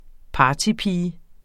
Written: partypige

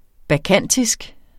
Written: bakkantisk

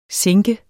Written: sinke

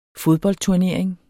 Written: fodboldturnering

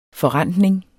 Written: forrentning

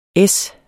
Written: es

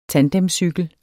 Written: tandemcykel

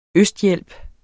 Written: østhjælp

